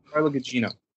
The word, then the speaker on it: Carla Gugino: Carla Gugino.